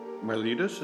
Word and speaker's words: Miletus: Miletus, is that how you pronounce it? – Yeah.